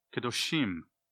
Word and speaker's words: Kedoshim: And it says Holy One, but in Hebrew it's actually plural, Kedoshim. Kadosh is the singular, but it says Kedoshim, and that kind of rhymes with Elohim, which is also plural masculine noun.